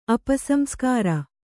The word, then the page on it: ಅಪಸಂಸ್ಕಾರ: Kannada